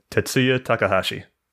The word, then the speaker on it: Tetsuya Takahashi: So other notable designers, including Tetsuya Takahashi. Did I say it right? – Tetsuya Takahashi.